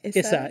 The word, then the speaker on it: Issa: Wait, how do I say that? Issa? – Issa. – Issa, yeah. Yeah, that's a standard Brooklyn thing is when you put like two words together.